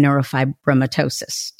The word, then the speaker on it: neurofibromatosis: I'm curious, when you've done research, have you been able to determine why they think there's a connection between ADHD and neurofibromatosis? Did I say it right? – Yeah, I think you did.